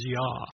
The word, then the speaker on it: Yah: We know that the first section of the name is pronounced as Yah. We know that from Scripture for a fact. We hear it in the word Hallelujah, which is literally praise the Lord or praise Yah.